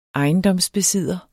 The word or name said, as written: besidder